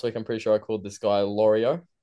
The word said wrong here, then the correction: Liero: Can I get a refresher on how to say his last name here? – Liero, there you go. – Like the Aero bar.